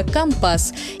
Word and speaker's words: компас: Так и получилось, что одно слово живет сразу в двух вариантах – сухопутное – компас и морское – компас. И в этом есть своя профессиональная логика. На флоте язык всегда чуть более строгий, точный и свой. И если услышали такое ударение, это уже не ошибка, это просто другой профессиональный язык.